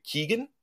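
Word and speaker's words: Keoghan: So the new Joker, I guess, is Barry Barry Keegan.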